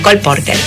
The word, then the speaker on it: Cole Porter: Cole Porter.